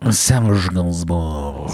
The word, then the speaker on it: Serge Gainsbourg: Serge Gainsbourg. – So spricht man ihn wirklich aus.